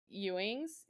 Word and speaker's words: Ewens: I think her last name's pronounced Ewens. It's E-W-E-N-S.